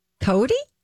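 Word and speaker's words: Coty: Rhymes with Schmody. – Cody? – Cody Cosmetics, right, Holly? – Yeah, yeah.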